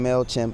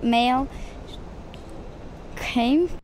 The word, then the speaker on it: MailChimp: And so people mispronounce MailChimp whenever they're doing it. – Support for Serial comes from MailChimp. – From MailChimp. – Mail. Came.